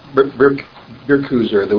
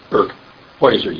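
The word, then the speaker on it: Birkhäuser: Carl and Linda, this guy, Berkuser, which is the Swiss Basel German pronunciation of Berkhäuser, you'd say in High German.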